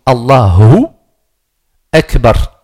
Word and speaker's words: Allahu Akbar: Il y en a qui disent Allahu Akbar dans le prononcer. Il n'y a pas de wa. Allahu Akbar. Donc Allahu Akbar.